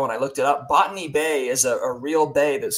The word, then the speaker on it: Botany Bay: And they say Botany Bay is how they say it in the song, but it's Botany Bay, like a botanist.